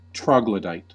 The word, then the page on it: troglodyte: American English